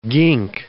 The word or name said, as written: gink